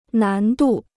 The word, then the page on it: 难度: nán dù